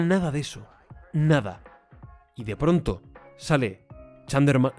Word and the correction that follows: Charmander: No, eh, Charmander. Charmander era, ¿no?